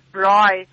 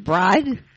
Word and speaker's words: braai: Is that you pronounce it bride? – Braai.